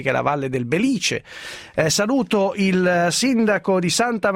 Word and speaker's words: Belice: Buongiorno sindaco, è così, è corretta la pronuncia? – Buongiorno a lei. – Belice. – Sì, diciamo sì, che anche è stato frutto del terremoto del 68, diciamo che oltre le case abbiamo perso anche l'accento.